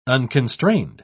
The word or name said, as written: unconstrained